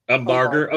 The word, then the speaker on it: Umbarger: Umbarger.